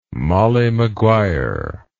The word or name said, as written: Molly Maguire